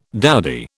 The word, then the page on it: dowdy: British English